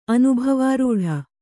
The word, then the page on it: ಅನುಭವಾರೂಢ: anubhavārūḍha